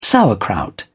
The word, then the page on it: sauerkraut: British English